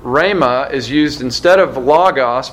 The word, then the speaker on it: rhema: The Greek term that is used is rhema. Uh, R H E M A is how you would spell it in English.